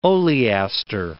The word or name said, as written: oleaster